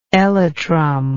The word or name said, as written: elytrum